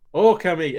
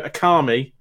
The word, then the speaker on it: Okami: And it says, Okami. It literally tells you how to pronounce the game. And just because people want to go Akami for some reason, like, it literally tells you how to pronounce the game at the start.